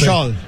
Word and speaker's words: Ischgl: ISCHGL, do you say the letters? You just Ishal. – Say? – Ishal. I call it Ishal.